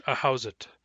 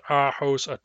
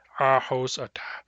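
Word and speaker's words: Ahousaht: So, for example, the Ahauzit tribe is called is its name is pronounced like I just said it in English, Ahauzit, Ahauzit. But its Nuchanoth pronunciation is Ahosata, Ahosata. If you're in the habit of pronouncing it Ahauzit, it can be very difficult to step back from that pronunciation and pronounce it the Nuchanoth way, Ahosata.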